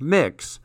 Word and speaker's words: Mx: And the UK recognizes MX, pronounced mix, as a gender-neutral title.